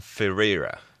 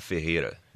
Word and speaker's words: Ferreira: Tordan Ferreira. – Tordan Ferreira. – Yeah. – Man, that's... – Yeah. Well, in Portuguese, I would say Tordan Ferreira. – Tordan Ferreira. – Ferreira.